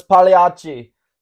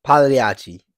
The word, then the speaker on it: Pagliacci: Oh, it's Pagliacci. – Pagliacci.